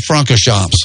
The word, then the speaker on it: Francorchamps: Spa Francisco or something like that. – Spa Francorchamps. – Francorchamps. – Yeah, close. You were in the ballpark with it.